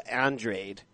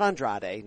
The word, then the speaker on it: Andrade: Andrade. Not Andrade, not Andrade, all right? Just like it sounds, Chardé, about why they crossed the street and took Eddie's money.